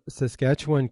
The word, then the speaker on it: Saskatchewan: Danny, you are from Saskatchewan, Canada. Is that correct? – That's right. And you have learned how to say it like a pro.